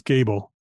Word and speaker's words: Gable: It's Gable. Yeah. Gable with no E, not an acronym.